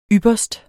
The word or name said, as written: ypperst